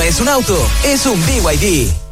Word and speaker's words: BYD: ¿No es BYD? – Nada que ver, se escribe BYD, pero se dice BYD, la marca número 1 de electromovilidad en Chile y el mundo.